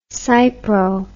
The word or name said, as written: cypro